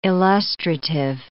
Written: illustrative

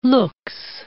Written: luxe

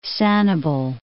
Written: Sanibel Island

